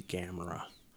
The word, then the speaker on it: Gamera: I think it must be Gamera. – It rhymes with camera, sort of.